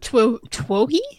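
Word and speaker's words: Twohy: I believe David Toy, is that how you say his name? David Twoggy? – Twoggy.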